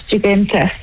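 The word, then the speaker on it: Dziuginta: My actual name is Jiginta.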